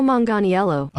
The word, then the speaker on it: Manganiello: How do we say Okay, how do you say his last name? I'm not really sure. – Let's find out. – Joe Manganiello. – Oh, Manganiello. – Stop it. I was not even close.